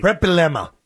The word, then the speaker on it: Bielema: Brett Bielema. – I can never say it right. – Brett Bielema.